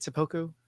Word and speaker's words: seppuku: More biopic should end in seppuku. How do you say it? Seppuku? – Seppuku.